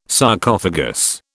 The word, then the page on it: Sarcophagus: British English